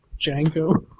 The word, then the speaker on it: Django: The tagline for this movie was, "The D is silent. Payback won't be." – That's good. That dude who approached him at the bar, he's like, "What's your name?" Django.